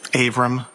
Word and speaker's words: Avram: Avram Mack.